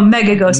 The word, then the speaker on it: Megos: keep an eye out for Megago. Did I say that right? Oh, Megagos.